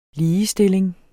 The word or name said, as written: ligestilling